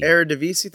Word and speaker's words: Eredivisie: There, I can never say this right. The Eredivisie, is that Eredivisie? – Eredivisie, thank you.